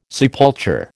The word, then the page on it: sepulture: American English